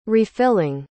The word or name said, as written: refilling